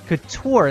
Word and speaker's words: Couture: How do you say Couture? – Couture, that's what it is.